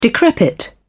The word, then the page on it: decrepit: British English